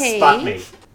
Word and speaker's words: SBATMI: So it says S-B-A-T-M-I, so I'm just saying that's S-P. Pronounced sputmi.